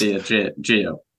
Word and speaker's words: Diageo: It is, and I'm pronouncing this, I hope, correctly, Diageo. Is that correct? – Diageo. I looked at the pronunciation before this because we're not going to go the whole episode mispronouncing this thing. I think it's Diageo. – Diageo. Diageo. Okay. Maybe like a shh.